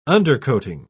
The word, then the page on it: undercoating: British English